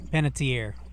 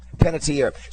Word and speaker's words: Panettiere: So, Hayden Pence, how do you say your last name again? – Panettiere. – Panettiere.